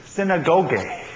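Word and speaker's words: synagoge (συναγωγή): The proper pronunciation is synagoge, and it means the bringing together.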